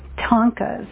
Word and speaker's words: Thangka: They're called Tonkas, but it's spelled like Thanga, T-H-A-N-G. K-A. My spelling is off, right?